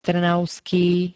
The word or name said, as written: trnavský